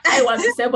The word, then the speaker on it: Aiwanose: I'm trying to get the Aiwanose, but don't worry, it's fine. Even Nigerians don't get the pronunciation of my name.